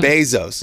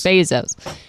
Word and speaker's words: Bezos: Bezos. – Bezos.